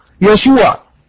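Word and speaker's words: يشوع (ישוע): النقطه رقم 2، النقطه رقم 2، قلنا ان يسوع في العبريه تنطق يشوع.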